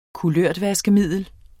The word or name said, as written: kulørtvaskemiddel